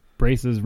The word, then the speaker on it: braces: Chris, braces rhymes with graces.